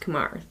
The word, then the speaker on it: Kumar: If you know even a handful of Indian people with Indian names, then you would know that it would be pronounced Kumar. That's how you would naturally want to pronounce it.